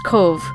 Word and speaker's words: Cobh: It's spelled C-O-B-H, but it's pronounced Cove.